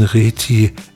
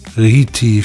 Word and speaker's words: Rieti: Übertraf die Tumorin die Norm der 15,30 Meter für die Europameisterschaften der U18 in Rieti, oder Rieti spricht man das aus, in Italien deutlich.